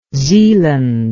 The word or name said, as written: zealand